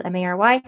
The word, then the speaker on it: Mary: Growthy, Grothe, first name Mary, as it's spelled Mary.